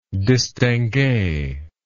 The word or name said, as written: distinguee